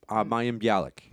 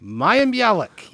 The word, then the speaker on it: Mayim Bialik: Mayim Bialik. – Mayim Bialik, yes.